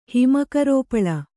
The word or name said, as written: ಹಿಮಕರೋಪಳ